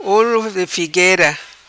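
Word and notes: Olo De Figuera: patua

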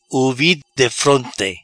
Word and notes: Uvi defronte: Patua

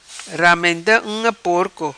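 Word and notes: Ramenda Unga Porco: patua

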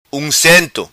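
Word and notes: Uncento: Macanese Patuá

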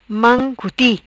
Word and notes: Mam Cuti: patua

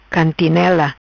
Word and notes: Cantinela: patua

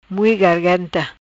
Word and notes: Mui Garganta: Patuá

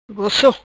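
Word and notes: Gosso: patua

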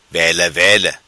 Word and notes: Vela Vela: patua

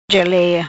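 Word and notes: Gelea: patua

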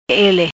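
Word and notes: Ele: Patua

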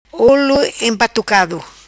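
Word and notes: Olo Empatucado: patua

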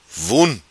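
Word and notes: Vun: patua

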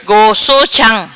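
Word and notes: Gosso Cham: patua

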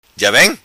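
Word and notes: Vem Ja: patua

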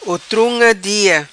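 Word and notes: Otrunga Dia: patua

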